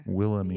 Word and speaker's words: Wilhelmina: Wilhelmina.